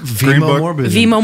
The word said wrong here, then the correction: Viggo: Oh, no. Viggo. V-I-G-G-O. – That's what we're saying. – That's exactly how I pronounce it. – No, that's not what you said. – I think I did. – Viggo. – Virgo. Uh-huh. – No. You're adding an R.